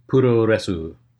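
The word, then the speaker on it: puro resu (プロレス): It's just puro resu. So yeah, yeah, it's just add some extra vowels in there to the US word, maybe truncate the, you know, an extra syllable or two, and you're good. You got it. You got the Japanese form.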